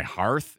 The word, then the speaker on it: hearth: My hearth? Mm-hmm. Yeah, my hearth. – rhymes with earth. – My hearth. – Sounds like a hearse, but with a lisp.